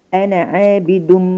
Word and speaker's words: أنا: لكن انا في حاله الوصل هقول انا عابد انا عابد انا بنطق نون مفتوحه وبعدين عيني مفتوحه ولا انا أنا عابد